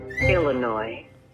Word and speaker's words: Illinois: Illinois. – S is silent.